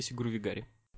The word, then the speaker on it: Sigurvegari: Еще один наш участник, человек с непроизносимым ником, как многие считают, это Никита Сигурвигари. – Shit. Всем привет. Хоть первый человек меня с первого раза правильно назвал.